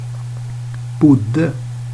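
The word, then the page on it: bodde: Swedish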